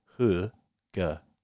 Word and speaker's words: hygge: And the awesome thing about doing that now is you get to call it hygge, which is a great word to say, and you can pronounce it hugger. Hugger.